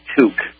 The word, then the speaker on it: toque: First of all, the Canadian version of it is pronounced toque. And I don't think there's a difference between a toque and a stocking cap except for the name, the cultural difference in name.